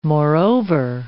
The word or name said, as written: moreover